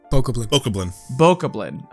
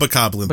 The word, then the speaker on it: Bokoblin: There's a boss, and I'm like, alright, because I did die once to, like, an elite Bokoblin? How do you say it? – Bokoblin. – Okay. – You can call it Bokoblin, though. That's what people used to say in Wind Waker days.